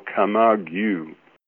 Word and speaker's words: Camargue: So it's the Rolls-Royce Camargue or Camargue? – Camargue.